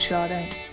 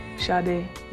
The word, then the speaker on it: Sade: Oh, I could have been called Sade, and I was called Sade. Sade, really. But in England, everybody goes Sade. In America, people tend to go Sade.